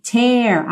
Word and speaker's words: tear: Lembra dessa pronúncia aí do verbo to tear, aberto, ok? Como se fosse um acento agudo no E.